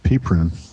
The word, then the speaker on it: PPRuNe: Or PPRUNE, is that how do you say it? – I just call it pea prune. – Pea prune, okay.